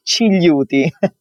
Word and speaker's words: Cigliuti: It's Cigliuti.